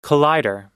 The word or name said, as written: collider